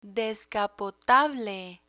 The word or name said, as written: descapotable